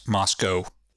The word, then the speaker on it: Moscow: And it is pronounced Moscow. – Moscow. – Moscow. – Yeah. They want to differentiate themselves. – Yeah.